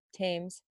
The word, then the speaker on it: Thames: The H is silent though. – Yeah. Thames.